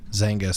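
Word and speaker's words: Xanga: Zanga spelled with an X, which is really just a blogging platform.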